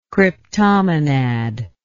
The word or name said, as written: cryptomonad